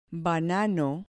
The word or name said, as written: banano